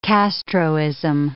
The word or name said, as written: Castroism